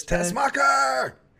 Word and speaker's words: Tessmacher: Miss Tessmacher. – Tessmacher.